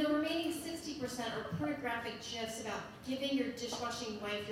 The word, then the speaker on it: GIFs: You will find literally pages and pages of videos, GIFs, this is pronounced GIFs, sorry, and images of how to properly load a dishwasher.